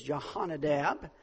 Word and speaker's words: Jehonadab: Jehu, and he is, the story's about Jehu, and he comes upon a well-known man, and his name is Jehonadab, and he is the son of Rechab, and so that's how we're going to pronounce those.